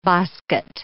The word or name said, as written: bosquet